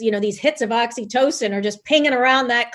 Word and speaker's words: oxytocin: Oxytocin. I'm glad you pronounced it because I'm reading it going, wait, how do you pronounce that? – Yeah. Not oxycodone.